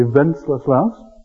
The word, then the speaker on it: Wenceslaus: I suppose if you're going to pronounce that correctly, it must be Wenceslaus.